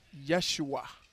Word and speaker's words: Yeshua: Oh, in Hebrew, Jesus is pronounced Yeshua, which sounds like Joshua.